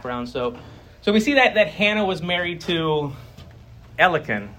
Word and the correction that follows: Elkanah: That's how you pronounce his name, Elkan. Okay, Elkanah, okay.